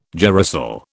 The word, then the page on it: girasol: British English